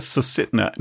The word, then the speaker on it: Susitna: Matanuska Susitna. Yeah, I guess you're Susitna. – Yeah, it's the Susitna.